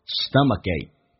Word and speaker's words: stomachache: 那么 stomachache 这个单词，第一个可以浊化成“葛” 这个发音，stomachache。最后一个“可” 可以不用发音，但气流阻塞一下。